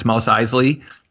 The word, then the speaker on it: Mos Eisley: It's Mos Eisley cantina.